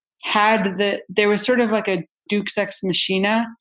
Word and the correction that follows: Deus Ex Machina: Duex Machina. I don't know how to pronounce it. I think Deus Ex Machina. – Yes, Deus Ex Machina,